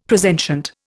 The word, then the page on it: presentient: Indian